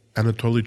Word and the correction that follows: atole: Ah, el atole and the champurrado.